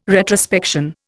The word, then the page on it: retrospection: Indian